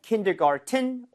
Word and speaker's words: kindergarten: 이것도 garden이 맞습니다. kindergarten인데 이게 현실적으로는요 garden 발음할 때 T 사운드를 거의 안 내요. – 아, 그래요? – 네, 그래서 kindergarten처럼 은처럼 발음하는 경우가 굉장히 많습니다. 그래서 들을 때 조금 주의하셔야 되기는 해요. – 네네. – So kindergarten or kindergarten 이런 식으로 연습을 좀 했으면 좋겠고요.